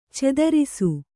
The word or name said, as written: ಚದುರಿಸು